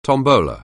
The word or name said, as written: tombola